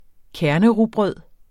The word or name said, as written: kernerugbrød